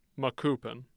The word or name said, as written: Macoupin